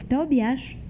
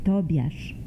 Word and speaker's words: Tobiasz: his name was Tobiasz, and I would look for approval into my father's eyes. His name was Tobiasz, and that's how we pronounce what you pronounce as Tobias, Tobiasz, so it rhymed.